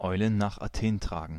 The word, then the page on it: Eulen nach Athen tragen: /ˈɔɪ̯lən naːχ aˈteːn ˈtʀaːɡən/; /ˈɔɪ̯ln̩ naːχ aˈteːn ˈtʀaːɡn̩/; /ˈɔɪ̯ln̩ naːχ aˈteːn tʀaːɡŋ̍/